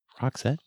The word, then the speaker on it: Roxette: Roxette? It's R-O-X-E-T-T.